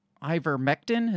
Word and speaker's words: ivermectin: And when we come back, we're going to talk about the deadly ivermectin. Is that how you pronounce it? – Yes.